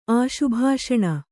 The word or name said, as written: ಆಶುಭಾಷಣ